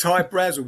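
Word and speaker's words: Hy-Brasil: There's High Brazil. High Brazil, people pronounce it High Brazil, and it's... – yeah, Brazil, okay. – Yeah, yeah. But it's High Brazil because that's how the Irish would pronounce it, because it's an it's an island that's off the southwest coast of Ireland.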